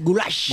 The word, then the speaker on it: goulash: El goulash es pronunciado así, goulash.